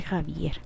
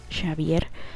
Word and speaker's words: Xavier: Eh, este, no sé, pero acá le decimos Javier. acá se pronuncia Javier. Em, pero los españoles le dicen Xavier o, sí, pero bueno.